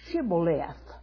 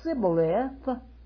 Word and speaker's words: Shibboleth: They couldn't get the H sound into that word, Shibboleth. And the ones who said Sibboleth were known to be Ephraimites because they could not pronounce it correctly.